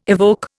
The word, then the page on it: evoke: Indian